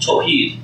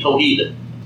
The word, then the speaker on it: Tawhid: It's called Tawheed, T-A-W-H-I-D, Tawheed, or T-A-W-H-E-E-D if you want to spell it that way.